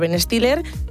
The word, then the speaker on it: Ben Stiller: La película pues es protagonizada por Ben Stiller.